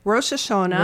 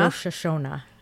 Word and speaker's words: Rosh Hashanah: Rosh Hashanah. – Rosh Hashanah.